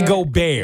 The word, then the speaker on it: Gobert: Gobert? – Rudy Gobert.